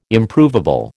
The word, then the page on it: Improvable: American English